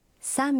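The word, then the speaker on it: Самбяку (三百): Правильно, но с произношением может возникнуть путаница. 300 произносится? – Самбяку.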